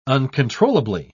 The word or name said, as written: uncontrollably